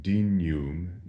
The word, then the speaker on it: dignum: In terms of pronunciation, notice that we say dignum, not dignum. Dignum would be classical Latin, but in ecclesiastical Latin, the GN is massaged a little bit, and we say it in the way that we would say in English, canyon, dignum.